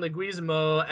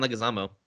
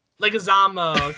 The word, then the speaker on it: Leguizamo: And the not late, kind of great, John Leguizamo as Luigi Mario. – Say that again. – How do you pronounce his last name? – See, it's John Leguizamo. – Leguizamo, okay. hey, you can't pronounce some names either, mister.